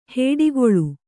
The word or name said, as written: ಹೊಡೆಗುಳು